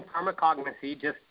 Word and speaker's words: pharmacognosy: Yeah, my background is in pharmacognosy. You actually pronounced it right. Very few people do. They think it's pharmacology or something like that.